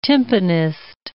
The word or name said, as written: timpanist